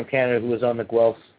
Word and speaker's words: Guelph: But Claude would like to correct you. It's Guelph.